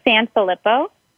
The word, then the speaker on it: Sanfelippo: Sanfelippo.